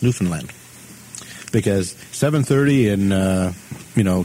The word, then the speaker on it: Newfoundland: I think, really, it's Newfoundland, you know. – Somebody once told me it rhymes with understand. Newfoundland, understand.